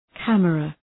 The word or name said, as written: camera